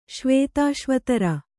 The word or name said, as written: ಶ್ವೇತಾಶ್ವತರ